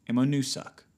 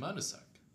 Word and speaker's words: Ammonoosuck: Yeah, it's ammo and then N-O-O-S-U-C. Ammonoosuck Road. – Ammonoosuck. – C could be silent. – Ammonoosu. – Who knows? I don't know. I'm just saying. – Ammonoosuck.